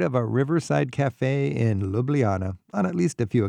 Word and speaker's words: Ljubljana: Ljubljana.